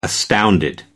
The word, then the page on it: astounded: American English